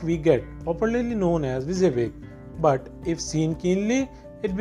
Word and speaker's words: WYSIWYG: WYSIWYG, pronounced WYSIWYG,